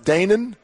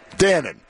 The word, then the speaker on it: Dannon: Is it pronounced Danon? Is that how? – Dannon. – Like the yogurt.